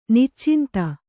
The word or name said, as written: ನಿಚ್ಚಿಂತೆ